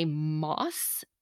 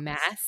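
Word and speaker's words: Maas: And a friend / listener pointed out that the author's name is pronounced Sarah J. Moss, not Mass.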